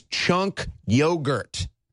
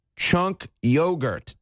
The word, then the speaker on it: Cenk Uygur: CNN can't even pronounce his name right. It's Cenk Uygur. Cenk Uygur. Okay, CNN, Brianna Kyler, Cenk Uygur.